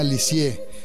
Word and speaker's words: Lisieux: Bueno, en la región donde ella vivía, en Normandía, la ciudad se llama Lisieux, así se pronuncia.